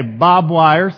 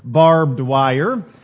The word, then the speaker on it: barbed wire: Yeah, we say bob wire, some of us, or bob were. And he says, of course, the correct pronunciation, if you really want to be technical, is barbed wire.